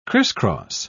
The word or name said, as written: crisscross